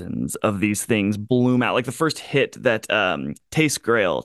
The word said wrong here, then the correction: Tattersail: am I saying that right? – Tattersail.